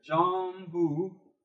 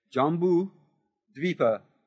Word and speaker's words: Jambudvipa: It's two words here. Jambudvipa. This is a long I. Dvipa. Okay? Jambudvipa. Technically the southern continent of Jambudvipa, and more particularly the central land of India, is a realm of deeds.